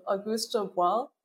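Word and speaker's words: Augusto Boal: I think, Dalida, if I'm, if I have this right, the scene was developed in dialogue with the ideas of Auguste Boal.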